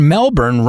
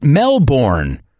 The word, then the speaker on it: Melbourne: Once we arrived here, I realized that I've been pronouncing the word Melbourne wrong my whole life. I always pronounced it Melbourne, but it's more like Melbourne.